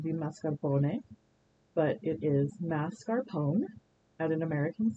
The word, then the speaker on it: mascarpone: Most people come out to the cheese counter looking for mascarpone, but it is actually mascarpone. The R is a little bit further into the word than we tend to think it is.